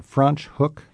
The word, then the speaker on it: Franschhoek: Franschhoek? – Yes, and that's spelled like, kind of like French hook.